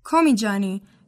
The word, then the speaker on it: Komijani: I'm Kimia Komijani. – Excuse me. How do you spell your last name? – Komijani. K-O-M-I-J-A-N-I.